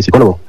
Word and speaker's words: psicólogo: Y otra cosa, no se dice psicólogo, se dice psicólogo. La P no se pronuncia.